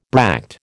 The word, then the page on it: bract: American English